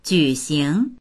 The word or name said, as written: ju3xing2